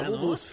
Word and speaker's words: Labubu: Ya sabes que estos muñecos que se llaman Lububus están como muy de moda. – La Bubus. – ¿No? ¿La Bubus? – La Bubus. – ¿Lo dije mal? – Dijiste Lububus como Bubulubus.